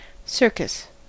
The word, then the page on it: circus: American English